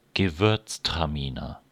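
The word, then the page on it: gewürztraminer: German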